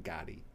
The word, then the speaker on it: Gade: But it turns out my name on my birth certificate is Nathan Eric Gaddy Dickman. G-A-D-E, pronounced Gaddy.